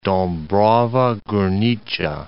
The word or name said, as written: Dabrowa Gornicza